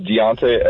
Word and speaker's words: Diante: So my name is Deontay Edwards, D-I-A-N-T-E, and Edwards is spelled normally.